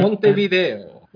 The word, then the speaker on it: Montevideo: I think it was like Montevideo or something like that. – Montevideo. – Yeah. Much better pronounced there.